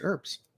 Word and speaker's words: herb: She just used herbs. So, just kind of interesting note there. – Herbs or herbs? – Herbs. Herbs. Herb. Herb. The H is silent. It's like whip, whip.